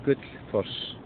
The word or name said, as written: Gullfoss